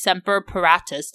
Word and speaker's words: Semper Paratus: Coast Guard is Semper Paratus.